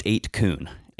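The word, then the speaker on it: 8kun: And so it is pronounced 8-coon.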